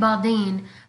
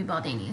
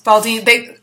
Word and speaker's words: Baldini: I've been saying it Baldine, but I think that's how they pronounced it in French, but I think in Italian it would be Baldini. Is that right? – Yes, Baldini.